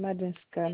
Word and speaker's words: Madinska: Madame Madinska, Marie-Chantal. – Alors, ça s'écrit, vous pouvez me l'appeler, s'il vous plaît? – Madinska. – Dinska. – D comme Daniel. Inska.